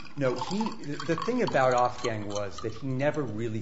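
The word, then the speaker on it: Ofgang: Or Off-Gang, O-F-G-A-N-G, I believe. – Okay, Off-Gang.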